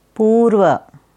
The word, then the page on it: Purva: Sanskrit